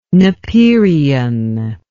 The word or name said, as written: napierian